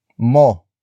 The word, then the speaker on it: mo: Puntahan mo ang ospital.